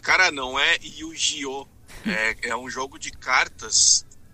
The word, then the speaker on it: Yu-Gi-Oh!: Yu-Gi-Oh!